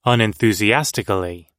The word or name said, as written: unenthusiastically